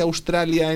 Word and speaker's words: Australia: Se escribe A-U-S, se escribe igual en inglés que en español, ¿vale? Australia. ¿Cómo se dice Australia en inglés? Australia. – Australia. – Empezamos con la O. Como una ostra, ¿sí? O. A-U es una O.